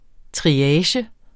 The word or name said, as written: triage